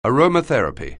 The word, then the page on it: aromatherapy: anglais